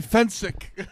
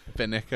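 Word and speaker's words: Fencik: It's Gary Fensick. – Fensick. Get it right. Oh, yeah. Finnick, I said.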